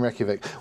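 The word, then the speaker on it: Reykjavík: I think it's Reykjavik.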